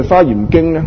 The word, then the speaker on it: 华严经: 好啦，咁而讲到呢个大方广佛花严经呢，大方广佛花严经呢简称就係呢、这个花严经，好多人都读作呢个华严经嘅。其实严格嚟讲呀，应该将个字读作呢个花严经嘅花，就定係读作呢、这个华贵呢、这个华字呢。原来呀，我哋中华人士，我哋华人呢、这个华字呢常时俾我哋读作呢个华字，冇错啊，中华嘛。但问题来讲，你知不知道这个华字是通这个花字的？